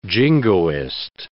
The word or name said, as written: jingoist